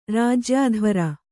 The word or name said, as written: ರಾಜ್ಯಾಧ್ವರ